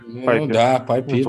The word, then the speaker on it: PyPI: Мне нравится второй пункт в наших темах, как раз про безопасность, о том, что в репозитории PyPI, или как он правильно читается, питоновского... – PyPI.